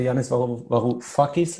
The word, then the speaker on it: Janis Varoufakis: der Janis Varoufakis, habe ich jetzt richtig gesagt? – Ja, richtig.